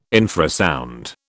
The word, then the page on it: infrasound: British English